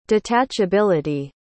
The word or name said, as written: detachability